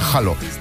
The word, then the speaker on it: Halo: Por cierto, se pronuncia Halo.